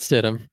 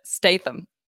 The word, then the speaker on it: Stidham: Jarrett Stidham. – I want to say Jarrett Statham. – That would be Jason Statham.